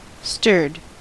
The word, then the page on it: stirred: American English